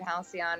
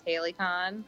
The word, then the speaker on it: halcyon: You know, like, my whole life growing up, I read the word halcyon wrong and thought it was Halicon. And I just pronounced it like that in my head all the time. Like the Halicon days.